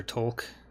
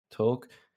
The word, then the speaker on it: Tolk: They also have, I don't even know how you say her name, Talk, I guess. – Called her Tolk. – Tolk.